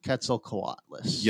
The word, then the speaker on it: Quetzalcoatlus: I might not even be right, but I call it Quetzalcoatlus. – Yes, I think you're right. You know what I'm saying? Say it again. – Quetzalcoatlus.